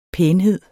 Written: pænhed

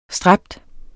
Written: stræbe

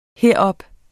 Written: herop

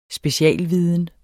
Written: specialviden

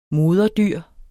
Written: moderdyr

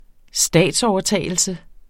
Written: statsovertagelse

